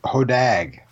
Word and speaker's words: Hodag: Hodag. Long A sound.